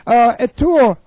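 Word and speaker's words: etoimazo (ἑτοιμάζω): And this is the word etoimazo. Etoimazo. Okay, E-T-O-I-M-A-Z long O. Etoimazo.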